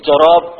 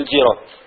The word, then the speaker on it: جراب: الخزانة والجراب بكسر الخاء والجيم، والقطع والقنديل بفتح القاف لا بكسر، لا تقول قنديل قل قنديل، لا تقول جراب قل جراب.